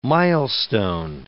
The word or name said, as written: milestone